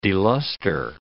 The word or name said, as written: deluster